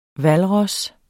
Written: hvalros

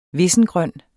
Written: vissengrøn

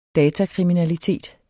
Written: datakriminalitet

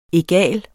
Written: Egale

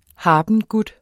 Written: habengut

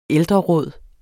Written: ældreråd

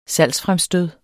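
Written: SALGSFREMSTØD

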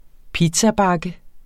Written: pizzabakke

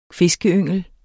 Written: fiskeyngel

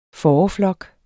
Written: fåreflok

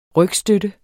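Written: RYGSTØTTEN

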